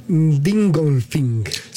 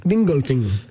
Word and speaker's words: Dingolfing: a ver si lo digo bien, Dingolfing. – Sí, lo dijiste bien. – Dingolfing.